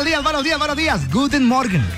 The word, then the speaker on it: Guten Morgen: En alemán se dice Guten Morgen. Guten Morgen. Escuchemos cómo se dice buenos días en alemán. – Guten Morgen. – Eso, sí. Guten Morgen.